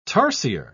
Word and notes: tarsier: British English

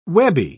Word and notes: webby: British English

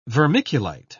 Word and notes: vermiculite: British English

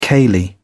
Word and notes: ceilidh: British English

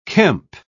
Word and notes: kemp: British English

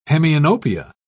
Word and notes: hemianopsia: British English

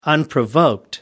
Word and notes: unprovoked: American English

